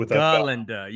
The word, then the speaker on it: Galinda: Oh, Galinda. You have to emphasize the ga. – You have to emphasize the ga. And of course, everyone at the school, when they see Galinda, they want to be her best friend because she's the it girl.